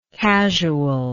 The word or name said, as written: casualness